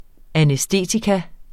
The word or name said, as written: anæstetikum